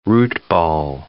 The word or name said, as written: root-ball